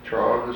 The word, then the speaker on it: trough: Trough is a... – Sort of pronounced like T-R-O-F-F, trough. Put it in the feed trough.